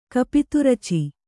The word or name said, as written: ಕಪಿತುರುಚೆ